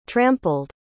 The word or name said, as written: trampled